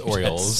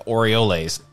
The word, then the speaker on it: Orioles: No, that's not Oreos. It's Orioles. – Smacks you in the head. No. Dang. They're pronounced the Orioles.